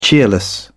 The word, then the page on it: cheerless: British English